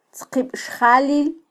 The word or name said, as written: tzqibxjalil